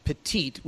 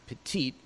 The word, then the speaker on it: Petit: She's a Belgian woman, first name Bertha, last name Petit, spelled Petite. We would pronounce it Petite, probably pronounced Petit in Belgium.